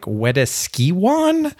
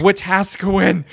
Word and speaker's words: Wetaskiwin: And we're like, Wetaskiwan. I'm like, no, it's Wetaskiwin.